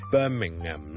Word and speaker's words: Birmingham: 譬如你报英国新闻，你会听到咧，佢哋个 H M 都系唔同嘅，所以呢个人名系 Jude Bellingham 银荷包银咧，当系类似，OK Bellingham Jude Bellingham，咁呢个 H M 咧系唔需要读出嚟嘅，咁当然你读埋出嚟咧，都唔系一定错嘅。如果你美国人咧，可能会读埋个 Ham 出嚟，Birmingham 咧就可能会读成 Birmingham。咁但系如果英国咧，大部分人咧，佢 H M 都系唔同嘅。